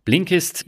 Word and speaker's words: Blinkist: Du hast mir doch letztens erzählt, du liest eine ganze Menge über ja, Blinkist hießen die, oder habe ich das jetzt richtig ausgesprochen? Genau, Blinkist lese ich jetzt schon seit, ich glaube, über zwei Jahren.